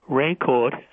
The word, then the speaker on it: Rancourt: I think one of them is, if you just Google Denny's name, D-E-N-I-S, Rancourt, R-A-N-C-O-U-R-T, when you pronounce it, the T is silent and the S is silent.